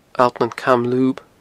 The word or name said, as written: Allt nan Camlub